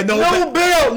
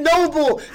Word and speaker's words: Nobel: Oh, yeah, Nobel. – Noble. – The Nobel Prize. – He pronounced it Noble.